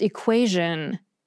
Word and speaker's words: equation: First, there's equation. That T-I-O-N suffix is usually pronounced with an S-H sound, but for some reason, it's usually pronounced with a zh sound in this one word, equation. Second, there's fission.